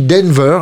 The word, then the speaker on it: DNVR: Alors ça s'écrit DNVR, ça se dit Denver a priori,